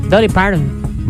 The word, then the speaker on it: Dolly Parton: Dolly Parton.